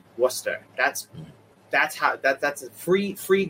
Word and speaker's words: Worcester: But what's how do you pronounce Worcester? – Worcester. That's how you say it with a Boston accent. If you wanted to say it in its proper English accent, you'd say Worcester.